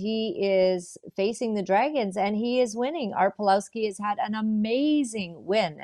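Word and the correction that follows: Pawlowski: and I'm saying his name wrong. It's Pavlovsky.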